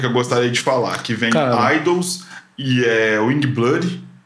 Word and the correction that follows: Youngblood: é assim que se é, pronuncia. Youngblood. Youngblood.